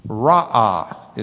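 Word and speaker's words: Ra'ah (ראה): Ra'ah is the way you might pronounce it.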